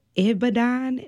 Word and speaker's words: Ibadan: He obtained his medical degree from the University of Ibadan, did I say that right? – You're perfect.